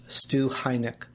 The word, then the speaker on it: Stu Heinecke: and it's pronounced Stu Heinecke. Did I get that right? You did. It's almost like Heineken beer.